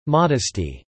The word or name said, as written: modesty